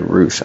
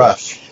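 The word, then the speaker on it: Rusch: You have athletes who, I'm thinking Rebecca Rush, I guess, as far as leading it, but is it Rush or Roosh? I just pronounced it horribly. – Rush.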